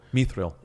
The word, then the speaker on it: Mithril: Mithril.